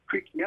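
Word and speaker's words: Creque: Now it's pronounced Creaky because I know that because I met James Creaky at the New York airport.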